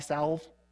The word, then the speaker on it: salve: They were known for like having a special eye salve with an L in there, silent L, eye salve.